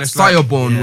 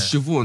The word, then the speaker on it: Siobhan: Say, like, you see the way, like, Siobhan is spelt, yeah? Like, Listen, I had to learn that. Siobhan was Siobhan. And so it's like, the same way...